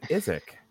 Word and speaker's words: Isaac: You know, what kills me about that is I wanted to call him Isaac, and I kept hearing them pronounce it as Izak. And I looked at my wife and I'm just like, I don't understand anything. And she was like, yeah, that's how they pronounce it over there.